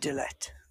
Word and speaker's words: Idelette: So, and he's happily married all the years of his life until Idelette, is that how you say it? – Idelette, yes.